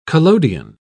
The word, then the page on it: collodion: British English